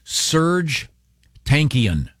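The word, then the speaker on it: Serj Tankian: Serge Tankian.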